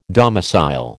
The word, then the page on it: domicile: American English